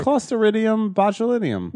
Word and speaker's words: Clostridium botulinum: I might have said that wrong. No, I did. I said it right. – Clostridium botulinum.